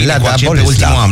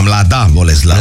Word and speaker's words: Mladá Boleslav: Nu știu dacă am pronunțat bine. – Mladá Boleslav. Mladá Boleslav. – Mladá Boleslav, așa, o echipă puternică de fotbal.